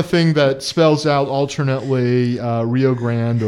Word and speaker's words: Rio Grande: So that's 6th and Rio Grande. – That's right. – Or Rio Grande, as we see on the border. – That's right. – Yeah, every place else will pronounce it Rio Grande.